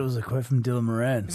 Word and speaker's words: Moran: Oh, he pronounces it Moran.